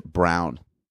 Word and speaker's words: Braun: There's a guy on the Nuggets whose name is spelt Braun, but he says it Brown.